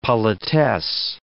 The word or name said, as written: politesse